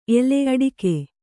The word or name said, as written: ಅಡಿಕೆ